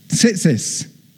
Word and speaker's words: Tzitzis: Throughout generations of Israelites, these tassels or tizzits, am I pronouncing it right? Tzitzis. Yeah. These tassels